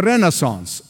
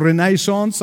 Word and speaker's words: Renaissance: The Reformation, and the Reformation came first, followed by the Renaissance, or Renaissance, as some have pronounced it. Either way is fine.